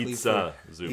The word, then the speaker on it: Ivica Zubac: Ivica Zubac.